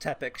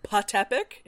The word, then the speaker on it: Teppic: Also, this is where we, like, see, like, he tells, like, this teacher his name, with, like, the weird fucking silent P at the beginning. And he's like, I can't pronounce that. It's just Tepic. Shut up. – Tepic, yeah. And the master's like, Patepic? And he's like, no, Patepic. And he's like, okay, Tepic. – Yeah. – And Tepic's like, that's what I said, but also I know the P is missing.